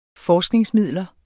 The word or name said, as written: forskningsmidler